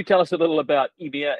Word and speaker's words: Ymir: Now, am I saying that right, Ymir, or is it more French, Ymir? – Yeah, in French we say Ymir mining, but I guess the correct translation for English would be Ymir.